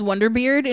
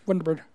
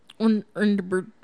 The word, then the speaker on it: Wonderbird: I pronounce it as Wonderbeard in my head. – That's the idea. It's Wonderbread without syllables. It's Wonderbird. – Wonderbird.